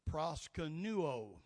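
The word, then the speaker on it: proskuneo: Proskenuo. That's, that's pronunciation right there, and that's the Greek word proskenuo. That's how you pronounce it.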